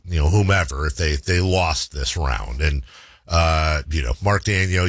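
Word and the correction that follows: Daigneault: Is it Mark Daniel? Mark, like Mark Daniel? – Dagnall.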